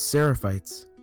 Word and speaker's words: Seraphites: No, it's not Sephrites. It's Seraphites. Did it again. – No, isn't it? – No, it's Seraphites. Well, that's how I pronounce it. Because you used to pronounce it saying Sephrites.